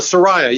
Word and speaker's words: Soraya: And it's at the is it the Soraya? Is that how you say it? – The Soraya, yeah.